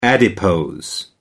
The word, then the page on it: adipose: American English